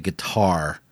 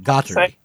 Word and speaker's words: Guattari: I always want to say guitar when I pronounce his name, which is wrong. – I never. – But Guattari, right? Is that how you say it? – But yeah.